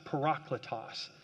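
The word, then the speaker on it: parakletos (παράκλητος): Jesus says, but when the Father sends the advocate, if you could circle that word, advocate, in the original Greek language, it's a word pronounced parakletos.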